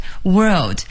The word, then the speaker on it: world: It's a small world， world 就是这个世界的意思。请朋友们注意 world 的发音，注意区别它跟这个 word 两个字的发音。